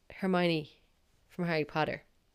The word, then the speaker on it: Hermione: So like when I started reading the books, like I read them in my head, and the movies obviously weren't out, so I'd never heard her name being pronounced, so I assumed she was called Herman.